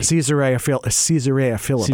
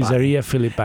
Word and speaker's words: Caesarea Philippi: Caesarea Philippi. – Caesarea Philippi.